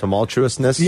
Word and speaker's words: tumultuousness: Tumultuousness? – Yes. Thank you. Thank you for saying that correctly.